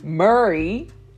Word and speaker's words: Murray: The noms are Mary Bartlett. I should say this right. It sounded like Mary. Murray, like Murray.